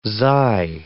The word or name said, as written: xi